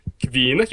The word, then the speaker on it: Kvinna: It's Swedish. – Swedish. Okay, so you would say it like a Swedish. You'd go, Kvinna, ja.